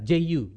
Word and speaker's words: Ju: How do you pronounce that? J-U. Ju. Ju. Okay, she's a Chinese master artist.